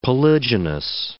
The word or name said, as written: polygynous